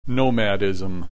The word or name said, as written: nomadism